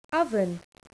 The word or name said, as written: oven